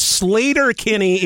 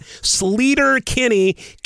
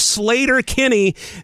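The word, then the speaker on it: Sleater-Kinney: It's Slater-Kinney. It is not Slater-Kinney. Carrie and her pals did not grow up like savages and pronounce it like Sleater. It is Slater-Kinney.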